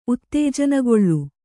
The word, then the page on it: ಉತ್ತೇಜನಗೊಳ್ಳು: Kannada